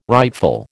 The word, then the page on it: rightful: American English